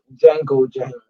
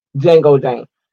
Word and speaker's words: Django Jane: I just played y'all Django Jane, because that's how it's really pronounced. Django Jane.